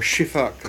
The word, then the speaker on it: Sifaka: Now, whether that's true or not, coming back to Madagascar, I'm fairly certain it's true of the Sifaka, or Shifak as it's pronounced in Malagasy, a lemur which does look quite a lot like a monkey.